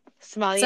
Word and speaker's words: sommelier: How do you say it? Sommelier. – Sommelier.